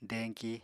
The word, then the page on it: deñki: apatani